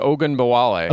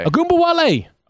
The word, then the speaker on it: Ogunbowale: Yeah, Dare Ogunbowale. – Ogunbowale. – Oh, okay. Did I say that right? – Yeah, close.